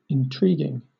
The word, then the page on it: intriguing: British English